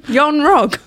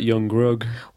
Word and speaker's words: Yon-Rogg: Yon-Rogg. – I prefer Blom Blom. – You see, I wasn't far off, though. Yon-Rogg. – Yes, anyone with two syllables. – Yon-Rogg. – That was like Yon-Grogg.